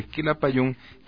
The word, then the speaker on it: Quilapayún: Querían una imagen más fuerte de la de los grupos tradicionales, así que buscaron un nombre indígena que tuviera un acento en la última sílaba, y se les ocurrió el nombre Quilapayún, que significa tres barbas.